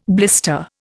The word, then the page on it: blister: Indian